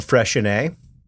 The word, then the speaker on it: Freixenet: Freixenet. – Freixenet? – Yeah, it's spelled like F-R-E-I-X-E-N-E-T.